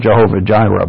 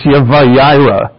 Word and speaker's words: Yahweh Jireh: Or we say Jehovah Jireh, but I'm learning that that's butchering of its pronunciation. It's Yahweh Jireh, meaning God provides.